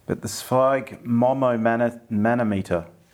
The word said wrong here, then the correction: sphygmomanometer: I think it's Zweig, Sphygmomanometer.